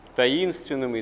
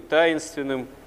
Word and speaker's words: таинственным: А нет, оказывается, все равно творение Божие, оно гораздо более является премудрым и сложным, и таким таинственным и таинственным. Можно и так, и так ударение ставить, смысл будет несколько изменяться.